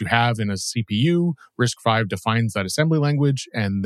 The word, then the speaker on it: RISC-V: The next bit of news is the addition of RISC-V, which is what Wikipedia says is the way to pronounce what I've always called RISC-V, as a supported architecture for CPython.